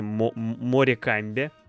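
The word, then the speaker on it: Morecambe: Это клуб Морком. Сложно так пишется по-английски. Морекамбе, Морекамбе, да, такое. Вот, ну, вроде Морком произносится.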